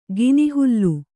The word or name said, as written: ಕೆನೆಹಾಲು